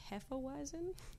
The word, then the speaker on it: Hefeweizen: I'm drinking a Boulevard Hefeweizen, is that how you say it? – Mm-hmm.